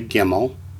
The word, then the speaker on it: gimel: Say gimel. Pronounced like the letter G in girl.